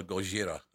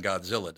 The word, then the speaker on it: Gojira: I love the fact they pronounce Godzilla Gojira. I love that. Gojira sounds a lot scarier than Godzilla, doesn't it? – Oh, 100%.